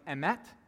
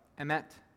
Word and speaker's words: emet: And the word, and this is an interesting thing about this, is the word in the Hebrew, it's pronounced something like emet, and it's where we get the word amen from. So emet, amen.